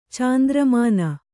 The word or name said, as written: ಚಾಂದ್ರಮಾನ